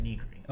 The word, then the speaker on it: Nigri: Lollipop Chainsaw is Jessica Nigri. – Yeah. Yeah. Why do you say it like that? – Isn't that how you say it? – Negri. – Oh, it's Negri? – Yeah.